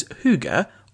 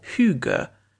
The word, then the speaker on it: Hygge: Pronounced as Hygge or Hygge, Hygge has no direct translation in other languages.